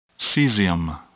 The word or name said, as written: cesium